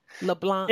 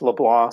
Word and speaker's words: LeBlanc: did i pronounce your last name correctly leblanc yeah, yeah the c silent i'm in uh, south louisiana so it it probably gets pronounced differently in other places, but but for us it's LeBlanc.